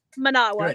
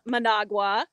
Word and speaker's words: Managua: Most Americans, Managua. But when you're here in Nicaragua, when you're here, you start listening Managua, Nicaragua. They don't pronounce.